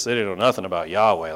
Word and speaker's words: Yahweh: This is that Hebrew word Yahweh. Way back in the day, people used to pronounce it Jehovah because they didn't know better, but this is that Hebrew word Yahweh.